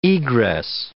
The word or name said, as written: egress